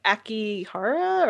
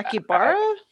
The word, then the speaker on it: Akihabara: Like Akihara? Or I forgot the name of that neighborhood. – Yeah. – Akihabara? – I can never pronounce it.